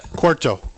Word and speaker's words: Cueto: There was Price, the different Cueto, or however he says it. I can't pronounce it. But Cueto. – Whatever it is. Cueto.